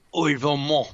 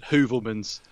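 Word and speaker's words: Heuvelmans: and he insisted on pronouncing Uvermont. Bernard Uvermont. That's Bernard Hoovelman's for those of you who are not Franco phones.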